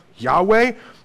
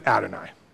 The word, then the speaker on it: Adonai: So contrary to the already confusing rules of Hebrew, when you come across the word YHWH, which you would normally pronounce Yahweh, you pronounce it Adonai. Just automatically, you pronounce it Adonai, which means Lord or King or ruler, right?